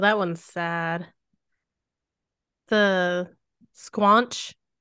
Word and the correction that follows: Squonk: Is that how you say it? Now I gotta look that up because I can't remember for sure. Squonk. Squonk. That's it.